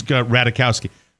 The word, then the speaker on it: Ratajkowski: It's Ratajkowski.